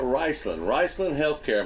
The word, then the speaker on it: Riceland Heathcare: But today we're talking about Riceland Heathcare.